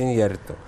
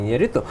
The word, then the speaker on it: Иньярриту: Правильно говорить, кстати, Иньярриту, а не Иньярриту.